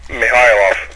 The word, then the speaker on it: Mihailov: Pretty good, Malice. It's Mihailov. – Mihailov. Okay, I was close. – You just put the emphasis on the wrong syllable.